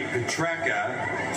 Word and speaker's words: Petrarca: Petrarca, 3 votes. – The guy's been playing for 7 years, And he's still got his surname mispronounced. – Instead of Petrarca.